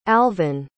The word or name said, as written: alvan